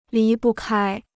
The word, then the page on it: 离不开: lí bu kāi